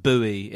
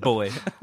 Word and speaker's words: buoy: I believe it's pronounced buoy in other countries. – Is it? – Yeah, B-U-O-Y. – Oh, catch up, everyone. Come on. – But we pronounce it boy.